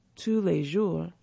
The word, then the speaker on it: Tous les Jours: Pronounced Tous les Jours, the bakery cafe chains dates back to 1996, and its first store was established in 1997 in Guri, near Seoul, South Korea.